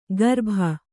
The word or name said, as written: ಗರ್ಭ